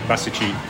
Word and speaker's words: Basich: And the little, where my actual grassroots from Dad comes from is called Basichi Selo, which is Basich Village.